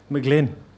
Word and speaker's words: Mag Fhloinn: Mag Fhloinn.